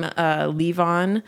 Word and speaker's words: Levon: I changed the spelling on mine just a little because I just thought it was easier to pronounce, because it kind of looked like Levin, and I didn't want that confusion. – Oh, I was just thinking about, I'm like, L-E-A-V-O-N? – It's just L-E-V-O-N is the Elton John song.